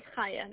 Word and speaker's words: Chaya: My name is Chaya. That's spelled C-H-A-Y-A.